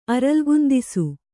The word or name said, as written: ಅರಲ್ಗುಂದಿಸು